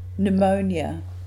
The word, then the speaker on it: pneumonia: Pneumonia, a disease of the lungs making it difficult to breathe. Note that the P is silent, so the first syllable is like new, pneumonia.